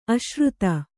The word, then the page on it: ಆಶ್ರಿತ: Kannada